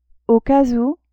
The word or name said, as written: au cas ou